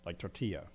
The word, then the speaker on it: tortilla: If I see a word that I know is in Spanish, I'll try to pronounce it in the Spanish way, like tortilla. A lot of people I know, they'll pronounce the L's in it. – Mm-hmm. – It's just tortilla. – Tortilla. And it just hurts my ears.